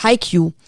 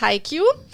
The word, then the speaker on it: Haikyuu: Na, du hast es schon mal ausgesprochen, das heißt Haikyuu. – Yay! – Sonst hätte ich das nicht gewusst, aber so habe ich das schon mal gehört, genau. – Schön. Genau, der Manga heißt Haikyuu.